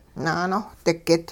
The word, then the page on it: naanohdigit: hupa